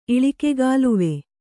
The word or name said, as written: ಇಳಿಕೆಗಾಲುವೆ